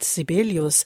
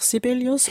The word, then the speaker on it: Sibelius: Und da man in Finnisch alles auf der ersten Silbe betont, wie ist das denn mit Sibelius? Sagen die Finnen da auch Sibelius oder wie? – Nein, der Name ist Schwedisch.